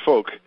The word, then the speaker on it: Fouke: His name was George Folk. So we call it a folk song because it was written by a guy named Folk. But it's spelled F-O-U-K-E is how George spelled his name.